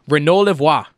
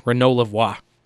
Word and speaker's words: Renaud Lavoie: Love saying his name. Renaud Lavoie. I took 5 years of French, so it gives me great joy to pronounce a man's name like that. Renaud Lavoie.